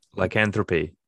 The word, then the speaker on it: lycanthropy: Lycanthropy.